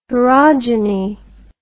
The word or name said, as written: sporogeny